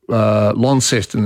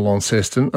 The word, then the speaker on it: Launceston: I just got actually an interesting email from Stephen who says the pronunciation of the Tasmanian city of Launceston is a good one. In England, and I've been to this, actually near Cornwall, they have the original Launceston and they call it Launceston. – Yes. – And here, some people say Launceston and some people say Launceston. So we've got three different pronunciations.